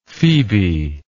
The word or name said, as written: phebe